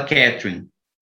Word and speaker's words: Catherine: Mas pelo que você citou, o Heathcliff tem um ciúme do Edgar pela Catarine, pela Catherine, né? Como se fala em inglês.